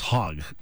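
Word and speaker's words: hog (חג): They begin with just the notion of holiday, which is, it's H-A-G, but it's pronounced hog.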